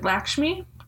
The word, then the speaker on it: Lakshmi: Lakshmi.